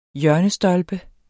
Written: hjørnestolpe

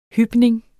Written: hypning

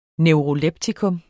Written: neuroleptikum

